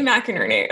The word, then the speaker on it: McInerney: It's Jay McInerney.